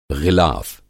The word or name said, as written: ग़िलाफ़